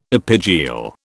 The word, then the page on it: epigeal: British English